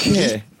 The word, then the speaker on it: Guy: Comment ça se prononce? – Guy. Non, c'est juste Guy.